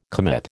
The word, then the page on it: commit: American English